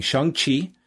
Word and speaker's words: Shang-Chi: Him and WH will be reviewing Shang-Chi. – Right? You got the proper pronunciation. That is correct.